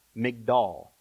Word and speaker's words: Migdal: The Hebrew word for tower there is, make sure I say it right, Migdal, M-I-G-D-A-L.